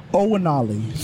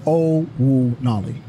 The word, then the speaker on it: Owonali: Owonali. So the N is silent in the name. So it's Owonali.